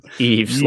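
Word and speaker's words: Yves: Yeast. – Eves. – Yeast Smith. Yeast Smith. – Eves. Like Christmas Eve.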